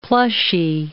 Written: plushy

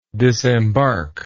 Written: disembarkment

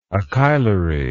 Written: acheilary

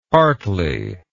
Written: hartley

